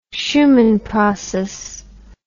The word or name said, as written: Shuman process